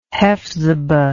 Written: hephzibah

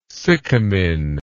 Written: sycamine

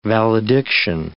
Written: valediction

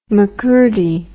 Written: Makurdi

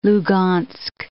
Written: Lugansk

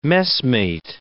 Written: messmate

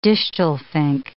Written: distelfink

